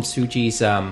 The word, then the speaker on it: Tsuji: Or James Chow gave me an explanation saying, in some instances, you can pronounce the T a little bit. – Tsuji. – But, yeah. – It's very subtle. Technically, the T is subtly pronounced. – It's subtly. Mm. And my mistake was taking a Canadian-style pronunciation and emphasizing the T.